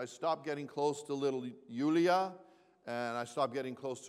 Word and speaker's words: Julia: It's actually spelled J-U-L-I-A, but officially, according to her dad's background, it's pronounced Yulia.